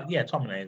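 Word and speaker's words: Tomine: I don't know how you pronounce his name properly. But like Adrian Tominay, is it Tominay? – Yeah, Tominay is what I've always gone for, yeah.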